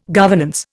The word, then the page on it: governance: Indian